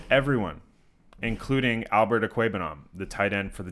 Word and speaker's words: Okwuegbunam: Okwuegbunam. That is the first and only time Neil is going to say his name correctly.